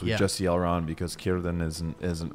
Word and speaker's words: Círdan: Círdan.